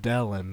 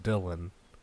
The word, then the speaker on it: Dellen: Yeah, it can be pronounced many different ways. His name is Dellen, not Dylan.